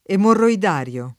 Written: emorroidale

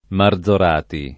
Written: Marzorati

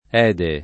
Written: Ede

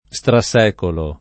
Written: strasecolare

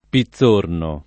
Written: Pitzorno

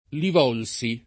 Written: Li Volsi

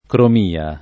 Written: cromia

